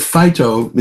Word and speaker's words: phyto: It's a way, the word phyto is spelled P-H-Y-T-O, plants, what it means. But it actually is pronounced like the word fight.